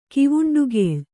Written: ಕಿವುಂಡುಗೇಳ್

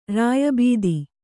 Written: ರಾಯಬೀದಿ

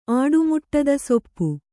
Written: ಆಡುಮುಟ್ಟದಸೊಪ್ಪು

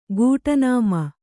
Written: ಗೂಟನಾಮ

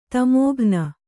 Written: ತಮೋಘ್ನ

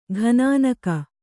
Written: ಗುನ್ನಿಗ

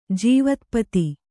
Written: ಜೀವತ್ಪತಿ

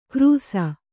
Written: ಕ್ರೂಸ

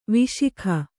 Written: ವಿಶಾಖ

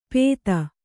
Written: ಪೇತ

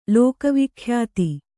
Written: ಲೋಕವಿಖ್ಯಾತ

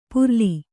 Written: ಪುರ್ಲಿ

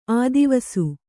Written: ಆದಿವಸು

